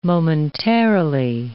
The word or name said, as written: momentarily